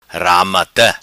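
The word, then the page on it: Ramata: patua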